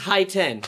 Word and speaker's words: Hiten: H-N-S-H-A-H. – There you go. Next time I'll do it. – And my name's Hiten. That's how you say it. And that's not how you say it. That's how you spell it. H-I-T-E-N.